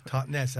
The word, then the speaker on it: Totnes: Yeah, it's the Totnes, as you say it properly, Totnes.